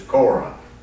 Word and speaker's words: Korah: Now, here, Korah is spelled C-O-R-E, is spelled different ways, but it's Korah.